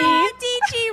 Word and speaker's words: Dietschy: Dietschy rhymes with peachy.